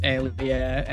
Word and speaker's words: aliyah: And yeah you know, there's like a whole historiography to this period with like the first aliyah, and uh, I believe that's how uh, it's pronounced. Yeah, and uh, all that stuff, where that's kind of how they uh, envision these things.